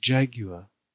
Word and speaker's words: jaguar: Playing D&D a couple weeks ago, and I said I was going to turn into a Jaguar. I was really stoned and was thinking like Jerry Jaguar. Like Jerry Maguire. Yeah. And one of the guys, it's pronounced Jaguar, but without a British accent.